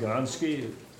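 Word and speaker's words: Gonske: I'm Bob Gonski. My name is spelled with S-K-E because I'm a German.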